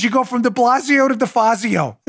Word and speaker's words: De Blasio: Speaking of morons like De Blasio rhymes with DeFazio.